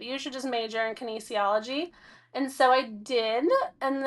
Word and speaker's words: kinesiology: Kinesiology. – Kinesiology, yeah.